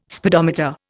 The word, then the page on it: speedometer: Indian